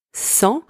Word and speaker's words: sang: Second word, sang, spelled S-A-N-G, means blood. In that word, the letter S is also pronounced S. The letters A-N together are pronounced en, and the final consonant G is silent. Third one, sang, spelled S-A-N-S, means without.